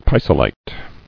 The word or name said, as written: Pisolitic